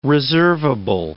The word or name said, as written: reservable